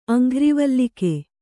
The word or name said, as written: ಅಂಘ್ರಿವಲ್ಲಿಕೆ